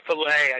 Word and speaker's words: Chick-fil-A: Well, Chick-fil-A, or filet, I suppose, as it's supposed to be pronounced.